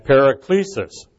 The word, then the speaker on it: paraklesis: That's spelled P-A-R-A-K-A-L-E-O and paraklesis, P-A-R-A-K-L-E-S-I-S.